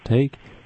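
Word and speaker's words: Thaik: Last name is pronounced Take, but it's T-H-A-I-K.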